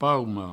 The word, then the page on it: paume: provencal